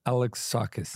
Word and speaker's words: Alexakis: What is the correct pronunciation, by the way? – Alex saw the band Kiss. Alexakis.